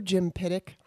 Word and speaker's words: Jim Piddock: Hello, Jim Piddock, Hollywood liberal elite.